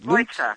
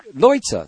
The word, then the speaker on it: Leutze: It's Emmanuel Lutz, is it? – Leutze. – Leutze, thank you very much.